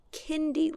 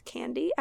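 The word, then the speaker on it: Kaindy: So our last hike is going to take us to Kindy Lake. I thought it was pronounced candy at first because there is an A in it, but apparently it's pronounced Kindy Lake.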